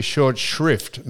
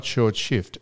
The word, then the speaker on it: short shrift: And the doc gave you short shrift, not short shift, short shrift. That's one of the most commonly mispronounced sayings. – What? What's the word? – It's not short shift, it's short shrift.